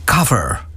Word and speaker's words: cover: Ik moet het eigenlijk een beetje in het Engels uitspreken. The cover.